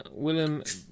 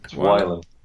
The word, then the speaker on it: Wylam: Right, as I said at the top of the show, it is Jakehead IPA from Willem Brewery. Is that how you pronounce it? Willem? – Wylam.